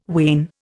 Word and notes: wain: Indian

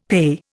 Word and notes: PAYE: Indian

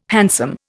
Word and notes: hansom: Indian

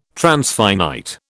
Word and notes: transfinite: British English

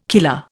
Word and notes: killer: Indian

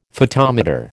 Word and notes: photometer: American English

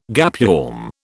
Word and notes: gapeworm: British English

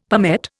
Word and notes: palmette: Indian

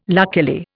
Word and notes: luckily: Indian

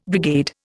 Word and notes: brigade: Indian

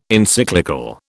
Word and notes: Encyclical: British English

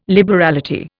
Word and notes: liberality: Indian

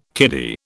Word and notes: kiddie: British English

